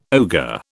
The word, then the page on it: ogre: British English